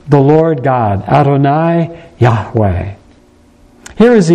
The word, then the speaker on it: Yahweh: But we are reasonably sure, and I'm probably more sure than most, it was pronounced Yahweh. Yahweh.